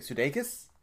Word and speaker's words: Sudeikis: I always think Sudeikis. – Yeah, is that right? – That's still the same.